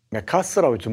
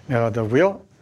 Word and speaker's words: 맥아더: 더글라스 맥아스라고 좀 발음하던데요. 맥아더고요.